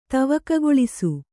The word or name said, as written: ತವಕಗೊಳಿಸು